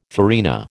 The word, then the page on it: farina: American English